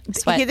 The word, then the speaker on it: Sweat: Is it Sweat or Sweet? – Sweat. They never said. – Okay, because it's Forrest, S-W-E-A-T. And that looks to me like Sweat.